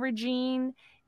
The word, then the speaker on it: regime: So Andre would refer to André Malraux, the French statesman and the author of Man's Fate about the 1920s communism regime in Shanghai. – Okay, I think we've had this conversation about regimen. – Regime. Did I say it wrong? What did I say? – You said regime.